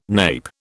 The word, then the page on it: nape: British English